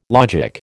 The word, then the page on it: logic: American English